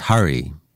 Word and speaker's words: Harry: Notice he says Harry, like the prince. My name's pronounced Hurry. Shop's given name is actually spelt S-W-A-P-A-N, and for years he let people mispronounce it, Swapan.